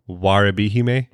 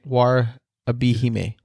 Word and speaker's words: Warabihime: How do you say it again? – Warabihime. – Warabihime. – Warabihime. So like, you remember Orihime from Bleach? – Who?